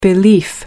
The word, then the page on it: belief: American English